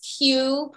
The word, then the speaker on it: Que: Well, my name is Q, pronounced Q-U-E, excuse me, spelled Q-U-E.